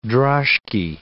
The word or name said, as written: droshky